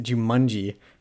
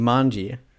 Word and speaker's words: Jumanji: I'm laughing because you pronounce it Jumanji. – How do you pronounce it? It's Jumanji.